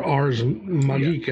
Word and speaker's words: Ars Magica: One of the games that made a huge impact on me as a game designer was the very first edition of Ars Magica back in the Dark Ages, or Ars Magica, yeah. I guess, if I'm going to pronounce it properly in Latin.